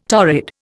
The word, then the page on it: torrid: Indian